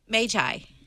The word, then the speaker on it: Magi: And again, how do you pronounce the word for wise men? – Magi. – Yes, because it's the plural of mages.